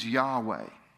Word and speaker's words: Yahweh: The Hebrew pronunciation was Yahweh, and it was sacred to them.